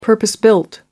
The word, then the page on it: purpose built: American English